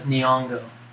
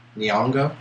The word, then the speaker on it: Nyong'o: Is it Lupita Yong? Is that N silent? yeah. I don't know. How do you say it? Lupita Nyong'o. Nyong'o? Okay.